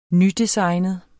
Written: nydesignet

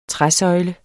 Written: træsøjle

